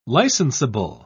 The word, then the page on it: licensable: British English